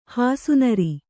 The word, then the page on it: ಹೊಸನೀರು: Kannada